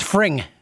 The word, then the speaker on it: Fring: And how did you say his name? Freed? – I think it's Fring.